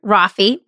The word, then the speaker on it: Roffey: moved me to tears because his name is Cameron Roffey, rhymes with coffee.